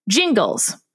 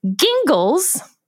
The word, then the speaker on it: Gingles: Jingles. 99% of the United States pronounces it as Jingles when they care to pronounce it at all. However, it's worth noting that Justice Alito pronounced it Gingles.